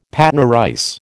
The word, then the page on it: Patna rice: American English